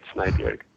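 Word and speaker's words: Snejbjerg: Is that how you say it? – Yes, that's how you say it, Snyberg.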